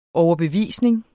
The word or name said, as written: overbevisning